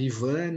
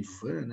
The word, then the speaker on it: Ivan: Eu falava I-V-A-N, ah, ah, Ivan, porque existe Ivan, né? Nos Estados Unidos. E aí, logicamente, depois de uma terceira e quarta vez, eu comecei a falar Aiva, né?